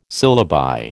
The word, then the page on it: syllabi: American English